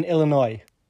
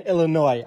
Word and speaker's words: Illinois: Yeah, no, well, people pronounce the letter S in Illinois. I didn't say the S in it.